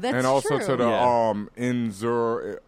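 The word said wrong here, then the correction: Zur-En-Arrh: I can never pronounce it. – Zur-En-Arr. – Zur-En-Arr, right? – Yeah.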